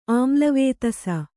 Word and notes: ಆಮ್ಲವೇತಸ: Kannada

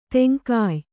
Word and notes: ತೆಂಗಾಯ್: teŋgāy

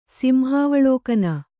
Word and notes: ಸಿಂಹಾವಳೋಕನ: Kannada

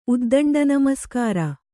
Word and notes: ಉದ್ದಂಡನಮಸ್ಕಾರ: uddaṇḍa namaskāra

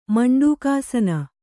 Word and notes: ಮಂಡೂಕಾಸನ: maṇḍūkāsana; kannada

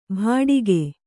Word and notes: ಭಾಡಿಗೆ: Kannada

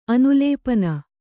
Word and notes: ಅನುಲೇಪನ: Kannada

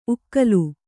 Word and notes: ಉಕ್ಕಲು: kannada

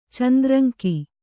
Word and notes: ಚಂದ್ರಂಕಿ: kannada

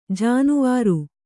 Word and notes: ಜೇನವರೆ: kannada

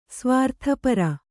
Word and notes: ಸ್ವಾರ್ಥಪರೆ: svārtha pare

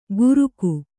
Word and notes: ಘುರುಕು: Kannada

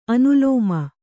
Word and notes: ಅನುಲೋಮ: anulōma

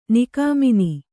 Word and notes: ನಿಗಮನ: Kannada

